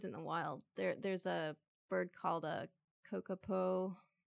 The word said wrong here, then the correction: kakapo: Kakapo? – Kakapo.